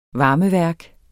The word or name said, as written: varmeværk